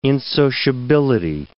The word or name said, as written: insociability